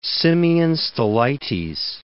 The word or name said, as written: Simeon Stylites